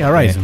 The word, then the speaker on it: Arisen: En eh, la siguiente noticia tenemos que Capcom anunció que el Dragon's Dogma Dark Arisen, eh, o Arisen, nunca supe cómo se pronunciaría. – Sí, Arisen.